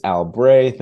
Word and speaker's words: Albrey: My name is Albrey.